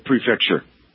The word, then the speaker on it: prefecture: They followed our lead, saying they're not releasing the info in those two counties or regions, prefectories. How do you pronounce them properly? – It's prefecture.